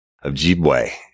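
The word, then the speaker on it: Ojibway: But it's Invisible Choir, and its host is Michael Ojibwe, and that's O-J-I-B-W-A-Y, and he says his name, he pronounces it just like it's spelled, right?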